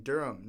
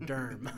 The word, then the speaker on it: Durham: Uh, we are giving a squeaky clean shout out to our listeners in Durham, North Carolina. – Yeah, yes. So not so far from here. Yes, and that is pronounced D U R M, Durham.